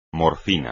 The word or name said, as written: morfina